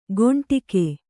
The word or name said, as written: ಕೊಂಡಿಗೆ